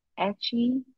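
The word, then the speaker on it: Aché: What I found a little strange in my research was this preventative measure I came across, the Northern Achi people. I mean, it's spelled like ache. A-C-H-E. The Northern Achi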